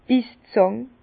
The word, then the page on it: d' Bisszàng: Bas-Rhin; Herrlisheim